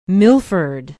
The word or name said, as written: milford